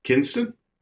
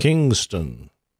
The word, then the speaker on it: Kinston: Oh, I mean, how does how do most people pronounce it? – Kingston. Yes. – No, it's Which Kinston. – Is incorrect. – But there's Kinston. no G in there.